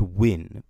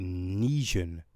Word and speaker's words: Nguyen: Although it's pronounced Wynn, but it's spelled N-G-U-Y-E-N. So, I don't know. I always thought that would be Nijan. But yeah, it's Wynn.